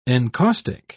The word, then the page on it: encaustic: British English